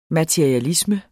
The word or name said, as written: MATERIALISME